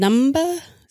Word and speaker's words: Numba: Travis is also a CEO of Open Teams and QuantSight and the founder of Anaconda and the creator of NumPy, SciFi, and Numba, is that how you say it? Okay, cool. Welcome, Travis.